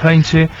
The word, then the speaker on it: Heinze: Bueno, esta noticia tiene bastantes meses, pero es para recordar por qué Gabriel Heinze, en realidad se pronuncia así, Gabriel Heinze, está en la selección.